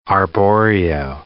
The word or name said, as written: arborio rice